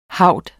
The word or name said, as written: haud